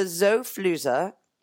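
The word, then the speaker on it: Xofluza: So the Zofluza, that is the correct pronunciation.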